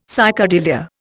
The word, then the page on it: psychedelia: Indian